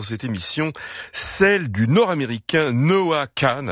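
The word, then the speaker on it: Noah Kahan: Noah Kahn, oui on dit Kahn, même si ça s'écrit Kahan. Noah Kahn donc a été cité 5 fois aux Grammy Awards en 2023, pour son album précédent, Stick Season.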